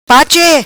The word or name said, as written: pache